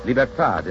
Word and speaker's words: libertad: Liberty is pronounced liberté in Haiti and Martinique, libertad in the islands that still keep the Spanish tongue.